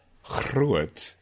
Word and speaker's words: groot: And so you could have a word like, it's spelled G-R-O-O-T, groot, which is groot,